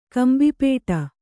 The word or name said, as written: ಕಂಬಿಪೇಟ